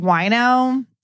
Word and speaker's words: Wojno: I mean, growing up with W-O-J-N-O, pronounced wino, I'm very sensitive to name pronunciations.